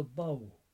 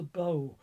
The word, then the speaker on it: badhbh: She's called the bow, B-A-D-H-B-H, to spell it in Irish. She tends to be called the bow in the southeast ish, that part of the country, whereas most people would regard her as the Banshee.